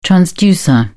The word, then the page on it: transducer: British English